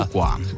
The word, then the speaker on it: Fuqua: Non prendiamoci troppo il gusto, perché martedì 23:20 c'è un altro gusto sulla 1, Training Day di Antoine Fuqua. – Fuqua.